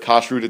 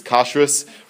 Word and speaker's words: kashrut: So some of the English spellings of the Hebrew are based on the Ashkenazi pronunciation, right? So like instead of kashrut, it's kashrus, right?